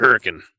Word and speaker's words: Hurricane: And Hurricane. – Yeah, Hurricane. Hurricane. – It's spelled Hurricane.